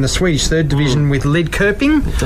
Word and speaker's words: Lidköping: It's pronounced Lidköping. – So, Lidköping. – Yeah, so the umlauts on the O is the sound. So Lidköping.